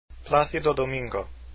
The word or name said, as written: domingo